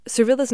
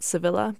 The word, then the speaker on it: Servilla: Just a quick note here, Servilla's name is spelled a couple different ways, but we think it might have been pronounced Servilla, not Sevilla, back in the day, so that's how we're saying it here.